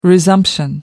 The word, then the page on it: resumption: American English